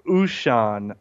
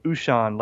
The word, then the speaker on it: Ushan: Yeah, I was calling it a Wuxan. That's what I was calling it. That's what I could not remember. But that's... You say the correct pronunciation is what now? – Well, they told me it's Ushan. Like, if you pronounce it O-O-S-H-U-N is how they said it was Ushan.